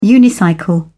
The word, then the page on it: unicycle: British English